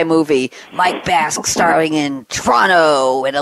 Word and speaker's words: Toronto: I had a guest on one of my radio shows yesterday from Toronto, and he pronounced it Toronto. He got rid of that first O. Is that the official pronunciation, Mike, Toronto? – The first O is kind of gone, and the last T is kind of gone, so it's Toronto.